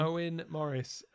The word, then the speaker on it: Owen Morris: Moen Morris? Is that the guy that's always cutting his lawn? – Owen Morris. Yeah, my pronunciation isn't very good because I can't even say the word pronunciation.